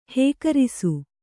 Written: ಹೀಕರಸು

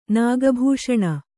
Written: ನಾಗಭೂಷಣ

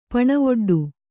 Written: ಪಣವೊಡ್ಡು

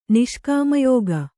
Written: ನಿಷ್ಕಾಮಯೋಗ

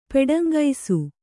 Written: ಪೆಡಂಗೈಸು

